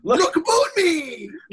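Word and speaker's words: Lookboonmee: That is legitimately, I'm staring at it right now, and it's spelled L-O-O-K, look, Boone, B-O-O-N, me, M-E-E. Look Boone me! Like, that sounds like something you yell when you jump off a water slide. – I mean, either it's look Boone me or look Bone me.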